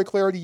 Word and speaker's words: Clardy: I'm Troy Clarity. You can follow me on Twitter at Troy Clarity. Last name is spelled C-L-A-R-D-Y.